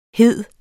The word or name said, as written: hede